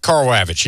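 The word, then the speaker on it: Karl Ravech: You have, now, forgive me for pronouncing his last name, Carl Ravitch. I think that's his name, right? He's a longtime MLB broadcaster of Baseball Tonight. – Okay. Carl Ravitch. Yeah.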